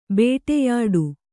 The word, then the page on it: ಬೇಟೆಯಾಡು: Kannada